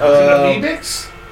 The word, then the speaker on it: Amebix: A-M-E-B-I-X? Yeah. Oh, Amoebix. – Is it Amoebix? Okay. – That's how I always pronounced it, Amoebix.